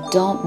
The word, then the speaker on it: temps: 时光流逝怎么说？Le temps passe，le temps passe，时间，le temps，d e m b s，它是一个特殊的发音，b s 都不发音，le temps passe。 pass b a s s e 是 pass 发生度过的变位，le temps passe 时间流过了，时间流逝。好，就这么一句话，非常简单，le temps passe，le temps passe，le temps passe。好，今天就到这儿了，明天再见喽。